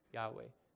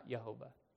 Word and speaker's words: Yahweh: It's probably a two-syllable pronunciation, Yahweh, not Jehovah.